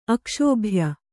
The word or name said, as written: ಅಕ್ಷೋಭ್ಯ